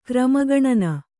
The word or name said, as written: ಕ್ರಮಗಣನೆ